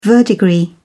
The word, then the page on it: verdigris: British English